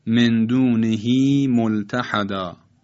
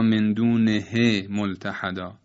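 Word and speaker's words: من دونه ملتحدا: در این دو مثال غلط است که تلفظ کنیم فی حکم ه احدا یا مندونه ه ملتحدا، بلکه هم تو عرض کردم به خاطر متحرک بودن قبل و بعد هاء ضمیر حرکتش اشباع میشه.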